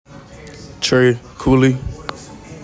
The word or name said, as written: TREY COOLEY